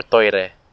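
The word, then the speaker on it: toire (トイレ): Or toire is how it's pronounced in Japanese.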